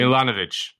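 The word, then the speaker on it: Milanović: I don't want to mispronounce his name. – Milanovic.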